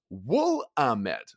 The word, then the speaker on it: Willamette: Willamette is pronounced Woolamette. So Woolamette.